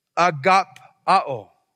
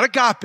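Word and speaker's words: agapao (ἀγαπάω): This word for love here is agapao. Agapao. It's not agape. We're going to talk about that in a moment. This particular word is pronounced a little bit differently.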